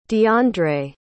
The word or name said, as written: diandre